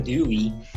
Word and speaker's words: Dewey: Eh, pero ya a nivel más depurado, este aprender haciendo de, Dawi, creo que era, eh, ¿cómo se pronuncia? – Dewey. Y, John Dewey.